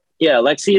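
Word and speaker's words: Alexy: Alexi is how you pronounce it for the Rangers.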